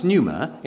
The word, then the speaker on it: pneuma: Now, the word for the Holy Spirit in Greek is pronounced pneuma. It's spelt pneuma, as in pneumatic tire, and it means wind.